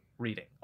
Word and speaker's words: Reading: Not pronounced like the verb Brett cannot do and the task Will excels at. Reading.